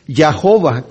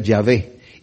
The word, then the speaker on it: Yahvé: En inglés tenemos Yahová, que realmente tomaron las vocales de Adonai, se las metieron entre la YHVH para que sonara Yahová, pero se cree que esa no es la pronunciación. Lo más correcto que sabemos que puede ser Yahvé.